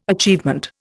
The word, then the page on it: achievement: Indian